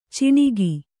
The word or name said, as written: ಚಿಣಿಗಿ